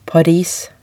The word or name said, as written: Paris